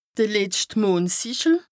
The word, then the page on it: d' letscht Mondsìchel: Bas-Rhin; Strasbourg